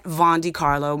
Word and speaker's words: Vondecarlo: You guys can always follow me on Instagram and Twitter at Vaughn DiCarlo. My name is spelled V-O-N-D-E-C-A-R-L-O.